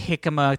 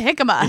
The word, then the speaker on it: jicama: It sounds like it should be spelled like hickory. – Yes. – But it's spelled like Jicama.